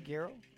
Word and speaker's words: güiro: Did I say that right, güiro? Excellent.